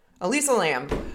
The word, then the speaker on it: Elisa Lam: That's an A. – No, it's E. – Elisa Lam? Oh. – I think I'm saying it wrong. Wait, let me check. Let me check. Let me check. I think it's Elisa. E.